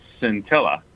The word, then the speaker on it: scintilla: Wicked Awesome Word is scintilla. – Ooh. One scintilla, yes. – S-C-I-N-T-I-L-L-A. A little bit of a fun spelling one here. – Yeah. – Mm-hmm. Throwing in that silent C in there somewhere.